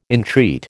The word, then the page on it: entreat: American English